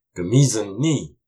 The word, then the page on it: Kumisun ni: Algonquian